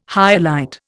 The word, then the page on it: hyalite: Indian